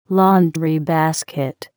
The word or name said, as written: laundry basket